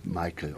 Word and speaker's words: Michael: It would be in German Michael Wüstenberg. Otherwise, they call me Michael over there. That's okay. Much more easy.